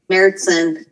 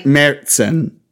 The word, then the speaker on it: Märzen: Märzen. Okay. – Märzen. There's a T in there that's obviously not in the actual word. – Oh. – apparently, that's how you say it.